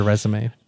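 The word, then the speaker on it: résumé: They didn't even look at a resume. They're like, we know you. – Yeah. Grant Randall. Well, no. This is embarrassing. It's pronounced resume.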